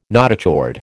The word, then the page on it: notochord: American English